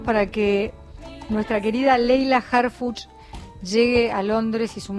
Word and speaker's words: Harfuch: Harfuch es H-A-R de Roberto, F de Francisco, U de Úrsula, C de Carlos, H de Héctor. Harfuch.